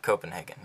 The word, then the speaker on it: Copenhagen: How do you say the fourth team in English? Is it Copenhagen? – Copenhagen, right? – Copenhagen, yeah. Because the thing is, in Swedish, we say it differently.